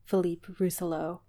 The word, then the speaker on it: Philippe Rousselot: So with a lot of these films, you may have known the director's name, but not necessarily known of Philippe Rousselot. I feel like my pronunciation gets worse every time I say it. – No, it's good. It's still good.